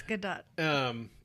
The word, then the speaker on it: Gadot: Isn't it Gadot? – I think it's Gadot. – It's Gadot.